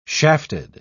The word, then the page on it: shafted: British English